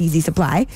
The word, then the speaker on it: Yeezy Supply: So it's actually spelled YZYSPLY, but pronounced Yeezy Supply.